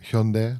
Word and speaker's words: Hyundai: Bedrijven als Samsung, Hyundai. Tenminste, dat is de Koreaanse uitspraak. – Hyundai.